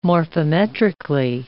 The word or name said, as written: morphometrically